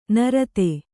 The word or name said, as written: ನರತೆ